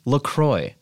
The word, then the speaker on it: LaCroix: Those assholes, if you go on their site, they say it's pronounced La Croix. – Sure. – Well, spell it C-R-O-Y then.